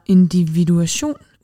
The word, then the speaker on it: individuation: Hvad er individuation? Udtaler jeg det rigtigt? – Ja, det er jo så også et nøglebegreb.